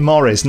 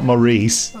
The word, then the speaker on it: Maurice: Sounds, I think he would have punched you in the face if he heard you call him Maurice. – It looks more like Maurice than Morris.